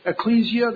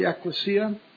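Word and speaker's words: ekklesia: Now, sometimes you'll hear people pronouncing it ecclesia. That's the Latinization of it. But the Greek is really ecclesia.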